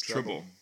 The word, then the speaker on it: Treble: Treble? Yes, Treble. – Treble. – Like dribble with a T, yes.